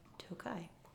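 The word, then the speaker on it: Tokaji: Tossing in the I at the end of that, it's still pronounced the same way, but it means it's of Tokaj.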